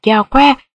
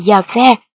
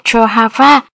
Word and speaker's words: Yahweh: Có người lại cố gắng phát âm rõ tên người là Yahweh, như những người nói tiếng Anh thường hay sử dụng, hay Yahweh, phỏng theo tiếng Pháp và tiếng Đức, hay thậm chí có người phát âm là Jehovah. Đây là cách phát âm sai,